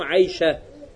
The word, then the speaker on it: Айша: А если останавливаемся, то говорим Айша. Так же, или Тольха, или Хамза. То есть там арбуту не произносим.